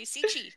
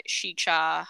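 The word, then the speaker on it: Sica: Yeah, because I wanted to say Sechi, because that's how I've heard it for so long. – Shecha.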